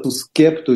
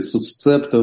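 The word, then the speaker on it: suscepto: Слово это имеет латинское происхождение, эксвоты или по-русски вотивы, эксвота сускепто или сусцепто в разных орфоэпических традициях, это латинское выражение, произносится, то есть по данному обету.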